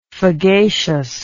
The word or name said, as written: fagaceous